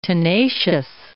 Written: tenacious

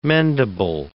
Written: mendable

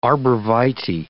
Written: arborvitae

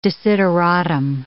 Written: desideratum

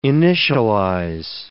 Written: initialize

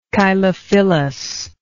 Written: chylophyllous

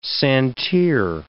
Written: santir

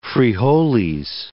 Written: frijoles

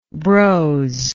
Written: brosy

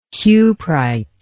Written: cuprite

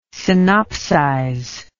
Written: synopsize

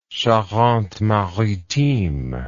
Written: charente maritime

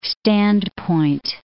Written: standpoint

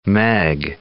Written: meg

